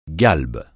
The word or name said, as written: galbe